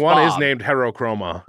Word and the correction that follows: Heterochromia: Heterochromia, you're right. – Heterochromia, I mispronounced it.